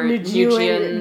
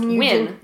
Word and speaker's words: Nguyen: but everyone likes to call them Nguyen or Nguyen or Nguyen. – Yeah. When it's just Win, like I'm winning.